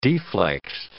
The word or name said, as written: deflexed